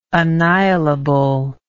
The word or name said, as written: annihilability